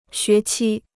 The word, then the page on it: 学期: xué qī